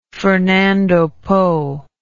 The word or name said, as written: fernando po